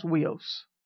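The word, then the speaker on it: huios (υἱός): In the Greek is the word huios. H-U-I-O-U-S, something like that. Pronounced huios.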